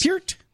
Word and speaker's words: Peart: You said his name was Neil Peart. Everybody calls him Neil Peart. – Well, it is not Peart. – It is Peart? – Yes, it is.